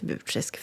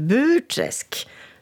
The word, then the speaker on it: Burträsk: Och jag vill gärna lära sörlänningarna hur man uttalar just ordet Burträsk. För många sörlänningar säger ju Burträsk, men så heter det inte. Det vore ju precis som att säga Västerås. Det går ju inte, utan Burträsk. Det är flera som har sagt att de önskar att boken ska bli inläst som talbok.